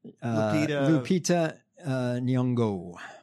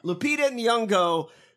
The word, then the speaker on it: Lupita Nyong'o: Lupita Nyong'o. – Lupita Nyong'o, great actor.